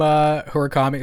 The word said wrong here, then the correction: Murakami: is it Hurakami? Am I pronouncing that right? Is doing visuals on. – Murakami, right? – Murakami, yeah.